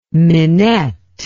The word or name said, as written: minette